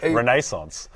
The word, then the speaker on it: Renaissance: They reached out to me and they said, I pronounce Renaissance correctly. That's how we say it in the Queen's English. Renaissance.